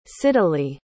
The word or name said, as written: siddalee